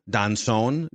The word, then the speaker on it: Doncon: I believe it's pronounced Rain Donchon, Donson, D-O-N-C-O-N. – Donson, I think.